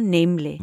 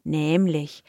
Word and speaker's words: nämlich: And it's the word nämlich, ja, nämlich. – Mm-hmm. – And the pronunciation of nämlich, because it's a long E, nämlich, suggests maybe that there is an H in there, but there isn't.